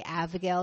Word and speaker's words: Abigail: By contrast, Abigail was, I'm trying to say Abigail because my daughter's name is Abigail. I'm trying to make sure I pronounce that B instead of a V.